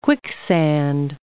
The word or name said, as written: quicksand